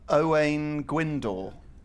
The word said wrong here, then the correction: Owain Glyndŵr: it's Owain Glyndŵr.